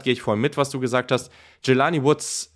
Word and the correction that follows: Jelani: ähm, äh, oder nee, jetzt habe ich ihn wieder falsch ausgesprochen hier. Julani, so heißt der.